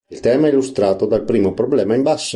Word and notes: problema: Italian

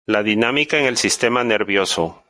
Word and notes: nervioso: ispanyolca

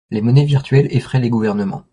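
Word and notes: effraient: french

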